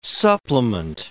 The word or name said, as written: supplement